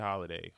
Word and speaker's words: Holliday: Facebook is AJ Holiday.